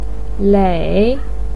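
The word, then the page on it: lei: tone 3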